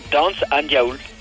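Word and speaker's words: Dañs an Diaoul: Tiens, d'ailleurs, le titre de l'album des Ramoneurs, comment tu le prononces? – Dance an Diaoul, voilà.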